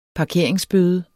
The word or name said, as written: parkeringsbøde